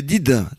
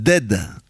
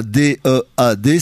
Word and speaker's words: dead: Tu dis Did? – Bah il y a Did, c'est pas Branded. – Non, c'est Dead. – T'es sûr de toi? – Un D E A D, ça se prononce Dead.